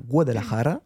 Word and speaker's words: Guadalajara: Elle était tête de série 29, notamment grâce à un titre qu'elle a gagné l'an dernier en septembre à Guadalajara. Voilà, avec l'accent, merci.